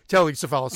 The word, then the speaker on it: Telly Savalas: I'm not going to spoil anything other than there's a Teddy Savalas reference in it. I got his name right, right? – Telly Savalas.